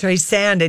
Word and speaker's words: Streisand: They pronounced it wrong. – Yeah, I know. – Streisand. – Not Streisand. Yeah. It's Streisand.